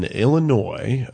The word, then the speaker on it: Illinois: And Illinois, right? – The S is silent.